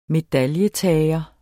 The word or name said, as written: medaljetager